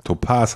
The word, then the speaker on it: Topaz: Topaz heißt das eigentlich. – Topaz? – Ja, nicht Topaz. Wieso egal. Nicht Topaz? So spricht man das aus. Du sagst ja auch Modus und nicht Modus.